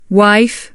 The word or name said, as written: wife